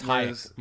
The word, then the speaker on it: Hájek: I mean, we talk more about Libor Hájek, and you don't even know how to say his name. – It's Hájek, Michael.